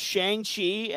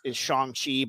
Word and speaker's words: Shang-Chi: Chris Davis, and we're going to be talking now about Shang-Chi and the Legend of the Ten Rings. I think the pronunciation they're trying to give us is Shang-Chi, but he's always been Shang-Chi to me.